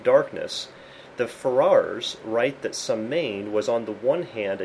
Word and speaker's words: Samhain: And of course, the one you're all familiar with is Halloween, which is pronounced Samhain.